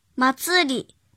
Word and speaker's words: 祭り: 训读的时候读作马兹鲁，马兹里。鲁和里是送假名，比如夏日祭，夏马兹里，夏马兹里，夏马兹里。写成日语汉字是夏天的夏加祭，然后再加平假名的里马兹里。